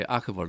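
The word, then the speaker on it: ARKVO: 叫 Arkable，Arkable，A R K 哈，叫 Ark，就是方舟。其实它的这个品牌 Arkable 的的。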